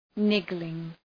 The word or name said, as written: niggling